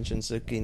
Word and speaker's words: Shinsuke: Shinsuke Nakamura because they're going to...